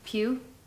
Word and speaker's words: Pugh: Pugh.